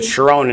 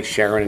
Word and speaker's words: Sharon: They pronounce it Sharon in Israel. We say Sharon in English.